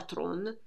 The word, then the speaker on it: Theron: La Tron, che si pronuncia proprio così nella sua lingua, come le stesse evidenzia, cade in depressione, ma anche in questo caso la madre è il punto di riferimento e di svolta cruciale per non arrendersi.